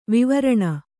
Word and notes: ವಿವರ್ಣ: Kannada

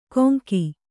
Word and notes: ಕೊಂಗಿ: Kannada

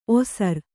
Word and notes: ಓಸರು: Kannada

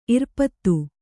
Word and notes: ಇರ್ಪದು: Kannada

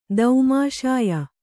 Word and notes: ದೌಮಾಷಾಯ: daumāṣāya

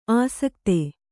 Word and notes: ಅಸಕ್ತೆ: asakte

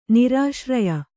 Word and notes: ನಿರಾಶ್ರಯ: nirāśraya

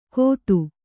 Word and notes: ಗೊತ್ತು: gottu

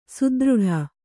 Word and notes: ಸುದೃಢ: kannada

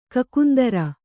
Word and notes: ಕಕುಂದರ: kakundara

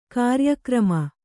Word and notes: ಕಾರ್ಯಕ್ರಮ: kāryakrama

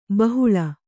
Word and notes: ಬಹುಳೆ: bahuḷe

